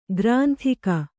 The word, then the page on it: ಗ್ರಂದಿಗೆ: kannada